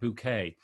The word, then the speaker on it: Bucket: Her name was Hyacinth Bucket, but she pronounced it Bouquet.